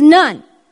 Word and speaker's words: nun: Now the Hebrew letter here, oh, I don't even want to try to pronounce it, is nun. Am I saying that right? Okay, it's nun. N-U-N. English N-U-N, obviously.